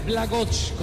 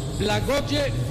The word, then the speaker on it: Blagoje: Se debe pronunciar Blagoch, como lo hizo Mauricio, pero nosotros en Colombia le decíamos Blagoje Vidinic, Blagoje Vidinic.